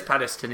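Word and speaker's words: Penistone: Roid Moor Wind Farm, located at Peniston, I think they pronounce it. – It is Peniston, yeah.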